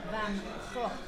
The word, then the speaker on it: van Gogh: Next on the list, let's go with Vincent van Gogh, as he is pronounced, because I speak fluent Nederlands.